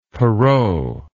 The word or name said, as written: perrot